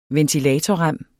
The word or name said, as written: ventilatorrem